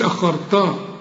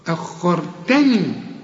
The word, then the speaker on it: أخرتني: لولا أخرتني، هنا بقى عاوز دقة في النطق، ما تقولش آه، ما تقولش أخرتا، أخرتني.